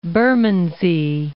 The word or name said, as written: Bermondsey